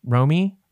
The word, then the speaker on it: Romy: What ended up happening was they brought in Rami. I never know how to pronounce her name. Romy from the XX.